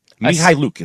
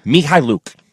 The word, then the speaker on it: Mihailuk: Mihailuk is how you say it. – What? – Mihaluk. I just heard him say it. – No. – Mihaluk. Yukaluk. – Mihaluk. Svi Mihaluk.